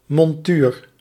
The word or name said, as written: chaton